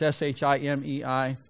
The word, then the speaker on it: Shimei: Do you remember the guy, Shimei, is how I pronounce it. It's Shimei.